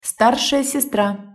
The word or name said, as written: starsaja sestra